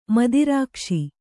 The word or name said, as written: ಮೂತ್ರಕೋಶ